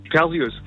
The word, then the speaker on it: Perseus: Was sagst du, Perseus oder Perseus? – Perseus. – So, eben. – Wirklich? – Sag ich auch. – Mein Leben lang falsch gesagt.